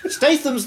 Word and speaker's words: Statham: Statham's the correct pronunciation.